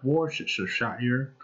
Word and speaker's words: Worcestershire: How do you pronounce that? Worcestershire. – You're doing a beautiful job.